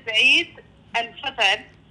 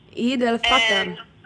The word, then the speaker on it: Eid el-Fitr: So it is Eid el-Fitr. – Eid el-Fitr.